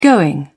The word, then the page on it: going: British English